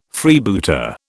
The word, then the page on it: Freebooter: British English